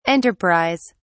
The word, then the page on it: enterprise: British English